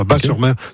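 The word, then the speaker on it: Batz-sur-Mer: Et surtout, la crêperie s'appelle le Dervin à Bas-sur-Mer, qui font les meilleures crêpes de la Oui, Bat, on prononce Bas, le T ne se prononce pas, Bas-sur-Mer.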